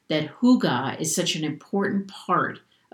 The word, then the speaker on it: hygge: It's spelled H-Y-G-G-E, but it's pronounced hygge, like being wrapped in a soft, warm blanket.